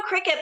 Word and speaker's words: Cricut: There's a company, it's spelled C-R-I-C-U-T. – Machines. – Yes. So you know Cricut, but I say Cricut.